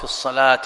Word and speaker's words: الصلاة: يقول قاعدة الزيادة مثل الواو في الصلاة والربا والمشكات كتبت بواو، الواو لا تنطق، فأنت تقول الصلاة